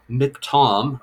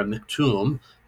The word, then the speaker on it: miktam: It is called a miktam or a miktum, depending on your pronunciation, which comes at the very beginning.